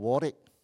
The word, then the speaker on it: Warwick: It's called Warwick. Don't ask me why it's pronounced Warwick. This English is very tough. The spelling is Warwick.